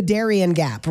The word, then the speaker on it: Darien Gap: And it is the Darien gap, right? That's how you pronounce that? – That's correct.